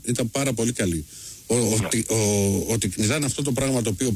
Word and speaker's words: Τικνιζιάν: Ο Τικνιζιάν με τον Τικνιζάν, γιατί δεν προφέρεται, λέει, είναι μένει με το αλφα, Τικνιζάν λοιπόν να το λέμε.